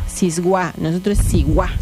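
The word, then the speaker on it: siwáj: Bueno, Siguá se escribe con la letra S.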